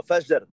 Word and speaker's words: Fajr: Fahir. – No, Fajr. – Shit. Oh, sorry. – A fajita, girl. We actually pronounce the J.